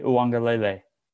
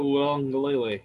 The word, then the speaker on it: Uiagalelei: My number 3 Heisman candidate is none other than Trevor Lawrence's backup, DJ Uiagalelei. – You said it right. There you go. – I did? – Yeah. – There you go. – I think so. Uiagalelei.